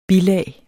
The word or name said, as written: bilags